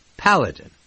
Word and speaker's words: Paladin: He mispronounced Paladin? I wrote it down and corrected. Paladin. Like his name, sort of.